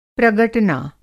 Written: प्रगटना

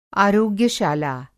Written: आरोग्यशाला

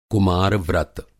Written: कुमार-व्रत

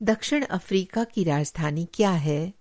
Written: दक्षिण अफ्रीका

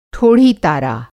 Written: ठोढ़ी-तारा

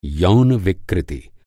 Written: विकृति

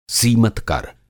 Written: सीमतकर

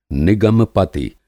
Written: निगम-पति

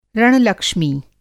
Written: रण-लक्ष्मी

